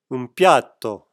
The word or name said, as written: piatto